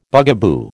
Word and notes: bugaboo: American English

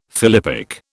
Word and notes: philippic: British English

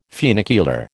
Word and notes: funicular: American English

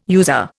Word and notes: user: Indian

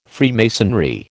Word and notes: Freemasonry: American English

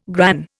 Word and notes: gran: Indian

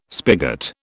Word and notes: spigot: British English

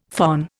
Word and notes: phon: Indian

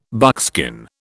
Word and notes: buckskin: British English

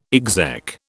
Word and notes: exec: British English

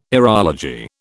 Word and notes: aerology: British English

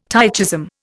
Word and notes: tychism: Indian

